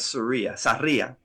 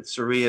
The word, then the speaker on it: Sarria: So my last name is Sarria in Spanish, you know, of course, but Sarria is how, you know.